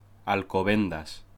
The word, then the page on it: Alcobendas: Spanish